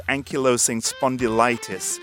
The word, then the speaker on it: ankylosing spondylitis: It's a degenerative bone disease called ankylosing spondylitis, which...